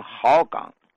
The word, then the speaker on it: 鹤岗: 黑龙江有一个地方叫鹤岗，可当地人读成好岗。